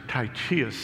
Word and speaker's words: Tychicus: So this Tychicus, or Titius, some pronounce it, is being sent probably with this letter to Timothy, who receives the letter in Ephesus.